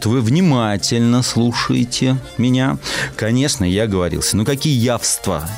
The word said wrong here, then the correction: яства: Яства. Да, спасибо вам большое, что вы меня поправили.